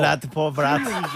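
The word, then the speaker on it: l'art pour l'art: 라트포브라트.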